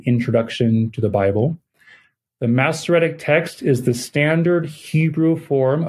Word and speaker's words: Masoretic: So, am I jumping ahead a little bit by asking you to define what Masoretic text means, if I'm pronouncing that correctly? – That's correct. Masoretic text.